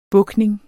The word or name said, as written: bukning